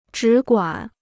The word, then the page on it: 只管: zhǐ guǎn